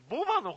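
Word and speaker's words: 牡馬: オス馬の方が品馬じゃなくて、あれですね、ボバの方ですね。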